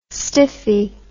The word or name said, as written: stiffy